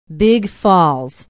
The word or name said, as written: big falls